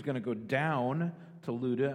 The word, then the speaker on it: Lydda: I know it looks like Lydda or Lydda, it would be pronounced Luda, so I'm going to try to pronounce that correctly as we go through, even though my mind says to pronounce it a different way. It's Luda.